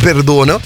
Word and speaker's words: XX Dono: Il 15 maggio è uscito Perdono, o meglio XX Dono, si legge come è scritto, però è Per Perdono.